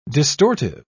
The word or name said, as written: distortive